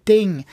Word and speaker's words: Ting: Je parle de Ting, qu'on prononce comme une pièce de monnaie qui tombe sur le métal. Ting!